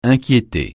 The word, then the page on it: inquiéter: francais